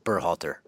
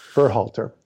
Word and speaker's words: Berhalter: Is that how you say it? – No K, just Berhalter. – Berhalter.